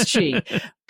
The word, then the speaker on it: Qi: One is the Hyperjuice wireless charge stand, because I was looking for a Qi charger. I said that correctly, right? Because it's like GIF and JIF. – Yes, it's Qi. – But yes. – It's Qi.